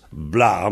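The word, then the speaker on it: bloaz: Et Blois se prononce Bla.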